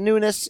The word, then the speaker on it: Nunes: Is it Nunes? Is it Nunes? It's certainly more Nunes. Amanda Nunes than Nunez. I do kind of float between the two,